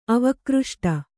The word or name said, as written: ಅವಕೃಷ್ಟ